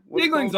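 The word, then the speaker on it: Yuengling: Is it Yingling? Yinger? – Yingling's all right.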